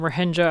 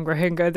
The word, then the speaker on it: Rohingya: I'll just note here, you're saying Rohingya, I'm saying Rohingya. They're both acceptable pronunciations for the group.